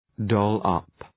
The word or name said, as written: doll-up